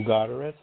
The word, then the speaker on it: Godderis: I hope I'm saying that correctly, Drew. I could be carving it up, though. – Well, you know, Jamie, I answer pretty well to anything. My name is Drew Godderis, sounds close, usually people say Godderis.